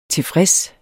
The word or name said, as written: tilfreds